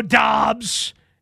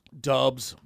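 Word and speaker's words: Doubs: Anyway, Romeo Dobbs. – Dubs.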